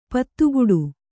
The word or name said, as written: ಪದಗುಡು